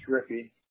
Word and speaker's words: Riepe: And your first name is Roger, spell R-O-G-E-R. Last name? – Yes. – R-I-E-P-E? – Yes, and that's pronounced Rippy.